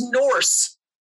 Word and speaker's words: Norse: Oh, she says Norse so weird. She can't say it properly. – I'm sorry. – Norse. – I thought Norse. I heard Norris. Just like, yeah. I was like, who? – That's how she says it. It's Norse.